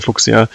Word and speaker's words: Fuchsia: I can't pronounce it the English way, and in German we would say Fuchsia, the Google microkernel project, which looks super interesting, and I'm eager to learn when they will make a product out of it.